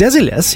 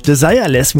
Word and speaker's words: Desireless: Die Band Desilets, ja, so spricht man es französisch aus, übrigens nicht englisch, Desireless, wie man vermuten könnte.